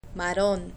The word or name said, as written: marron